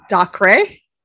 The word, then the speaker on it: Dacre: His name, his actual name is D like David, A-C-R-E. How would you pronounce that? – Dacre.